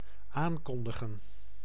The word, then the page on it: afficher: [a.fi.ʃe]; France